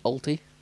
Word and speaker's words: Auty: Peter Alty. I think I'm saying that right. A-U-T-Y? Alty. – Alty.